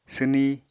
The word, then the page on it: swni: apatani